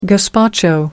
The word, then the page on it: gazpacho: American English